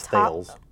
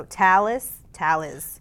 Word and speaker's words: Thales: I thought it was Thales. – I thought it was Thales too, okay? – Maybe it is Talus. – Wayne Price, our reporter, says it's pronounced T-A-L-I-S. So Talus.